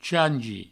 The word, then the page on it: changi: provençal